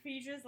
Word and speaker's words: Feige: Wait, is that how you pronounce Kevin Feige's last name? – Yes, it's Feige.